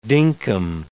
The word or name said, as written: dinkum